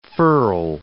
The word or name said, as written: furl